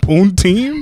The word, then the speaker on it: poutine: Poutine? – Poutine.